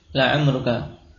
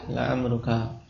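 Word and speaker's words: la amruka (لَعَمْرُكَ): Dan kekhususan ketika jadikan kosam ini tidak dibaca kecuali dengan fathah. la amruka.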